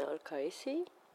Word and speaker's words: Al-Qaisi: Mm, my full name is Yasmina Al Kaisi. I have no idea how to pronounce my name for different reasons.